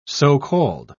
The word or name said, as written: so-called